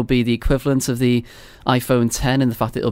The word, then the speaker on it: iPhone X: Because obviously Apple launched, what, three phones just a few months ago, but then you had one, the iPhone 10 or the iPhone X, depending on how you say it. I'm sure you'll correct me on that one in a minute. – It's 10.